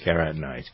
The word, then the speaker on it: carotenoids: Is that how you pronounce that? Yeah, it is carotenoids.